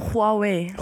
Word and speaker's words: Huawei: Huawei. – Huawei. – Huawei, ja. – Huawei. – Huawei, ja. – Huawei. – Also, Huawei. Also nicht die, also schon ein H, aber es ist ein bisschen härter. Huawei.